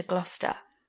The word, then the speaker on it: Gloucester: All I know about Gloucester is, for our international listeners who want to pronounce it Gloucester, just remember the rhyme. Dr. Foster went to Gloucester. Gloucester rhymes with Foster.